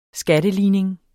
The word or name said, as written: skatteligning